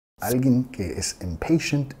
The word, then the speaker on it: impatient: Impatient. ¿Cuál sílaba estamos enfatizando? Impatient. La segunda, ¿okay?